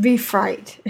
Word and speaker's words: befreit: I stay quiet, which she did, which, as Beffrey will tell you, is much better for everyone. oh, it's pronounced befreit. Thank you. German word meaning freed.